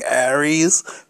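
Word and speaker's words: Ares: It was called, like, Aries. It wasn't spelled like the star sign. It was A-R-E-S. A-R-E-S.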